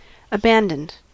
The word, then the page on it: abandoned: American English